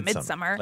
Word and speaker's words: Midsommar: Am I saying it right? Midsommar. – You can just say Midsommar.